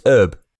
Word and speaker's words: herb: You don't pronounce a H in herb, do you? It's herb.